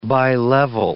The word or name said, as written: bi-level